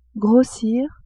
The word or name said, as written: grossir